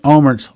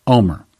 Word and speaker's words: Olmert: Ehud Olmert's last name is spelled O-L-M-E-R-T. So the American tongue would pronounce that Olmert. But that's not the way they pronounce it in Israel, and I've heard several people in Israel pronounce his name. They pronounce it Omer, which is the same word that she heard.